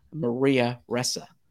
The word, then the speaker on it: Ressa: I want to get the pronunciation correct. Maria Ressa, R-E-S-S-A.